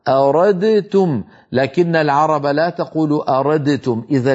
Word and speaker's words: أردتم: فعندما يأتي دال ساكنة بعدها تاء ندغم الدال في التاء فيصبح النطق بتاء مشددة ولا يبقى للدال أثر في النطق. هكذا أردتم، أردتم النطق تاء مشددة ولا يبقى من الدال في النطق شيء، تبقى الدال في الخط فقط.